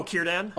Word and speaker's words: Círdan: And what about Sidran? I'm probably saying it wrong. – Oh, Círdan? – Oh, Círdan, sorry. Círdan.